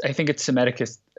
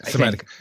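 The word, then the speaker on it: Psammetichus: I mean, I'm thinking in particular in this one, and you'll have to correct my pronunciation, the miraculous discovery of, is he Sam Ethicus the first? Do we leave out the P at the top? – I think it's Semeticus the first. – Semeticus, right.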